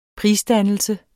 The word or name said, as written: prisdannelse